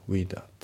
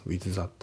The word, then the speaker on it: with: であと発音ですけど、 with のね、その th が抜けますね。 with that、 with that じゃなくて with that。